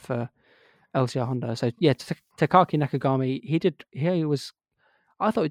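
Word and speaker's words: Takaaki Nakagami: Takaki Nakagami. – Takaki Nakagami.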